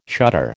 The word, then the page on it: shutter: American English